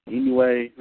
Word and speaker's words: Inoue: Here, he mainly worked tag matches, often with Stan Hansen, Kendall Windham, or Dr. Death Steve Williams, but also defeated Mighty Inoue, is it pronounced Inoue, I think it is? – Yeah.